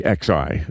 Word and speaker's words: Xi: Xi, X-I. – Of like, communist China. Yeah, it's spelled X-I, pronounced Xi like she went to the store.